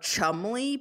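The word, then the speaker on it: Cholmondeley: A very long Marchioness of Chumley. But it does not look like Chumley. – It's spelled different. – Yeah, but it's pronounced.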